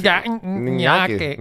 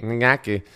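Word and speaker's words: Ngakoue: Ngake. Ngake. So wird ausgesprochen.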